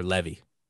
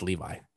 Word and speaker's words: Levi: Zachary Levi or Levy. I think it's Levi. He pronounces it.